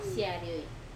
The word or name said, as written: arioi